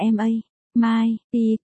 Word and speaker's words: MMP: Thích Hà lúc ấy liền, MMP, mụ bán phê, phát âm là MA, Mai, P, có thể viết thành từ viết tắt MMP.